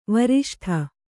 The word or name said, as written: ವರಿಷ್ಠ